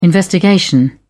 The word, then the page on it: investigation: British English